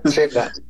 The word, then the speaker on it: Xela: Xela, se me va un poquito la pronunciación. – Xela. Es en Quetzaltenango, Guatemala, sí.